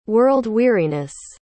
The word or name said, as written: world-weariness